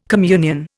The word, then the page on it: communion: Indian